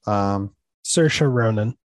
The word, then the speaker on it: Saoirse Ronan: Saoirse Ronan is her pronunciation.